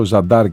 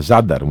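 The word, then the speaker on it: Zadar: Του λέγα εγώ Ζαντάρ και όχι ψέματα, Ζάνταρ μου λέγε αυτός. Εγώ λέγα Ζαντάρ, αυτός μου λέγε Ζάνταρ.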